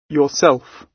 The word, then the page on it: yourself: British English